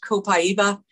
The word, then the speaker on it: copaiba: It's copaiba, or some people pronounce it copaiba.